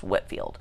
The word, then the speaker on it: Whitefield: It's spelled like Whitefield, but it's pronounced Whitfield.